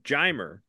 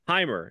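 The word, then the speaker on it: Jeimer: And I ask this guy in Spanish, I'm saying, why do you say his name Jimer? Isn't the J, huh? Isn't that the sound it makes? Shouldn't it be Heimer? And he goes, no.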